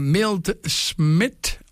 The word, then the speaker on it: Milt Schmidt: Il y a Milt Schmidt, on dit comme ça, oui.